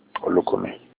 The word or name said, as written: Olukumi